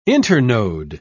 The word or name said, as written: internode